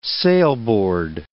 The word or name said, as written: sailboard